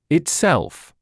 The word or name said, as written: itself